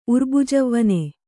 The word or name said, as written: ಉರ್ಬುಜವ್ವನೆ